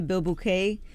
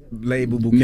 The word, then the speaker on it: bilboquet: I don't think that's right, but go ahead. We, we know what you, you mean. You pronounce it B I L B O Q U E T.